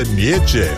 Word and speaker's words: Nietzsche: Si scrive Nietzsche, si pronuncia Nietzsche.